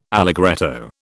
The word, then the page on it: allegretto: British English